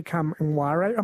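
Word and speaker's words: Kngwarreye: This is Emily Kamwara.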